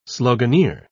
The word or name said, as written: sloganeer